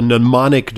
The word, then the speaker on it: mnemonic: It's what is called a mnemonic device. It's one of those weird exactly. words that starts with an M and MN, yes. – mnemonic, but yeah. the M is silent.